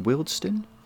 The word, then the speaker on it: Wealdstone: Wealdstone? – Yeah, I know who you mean.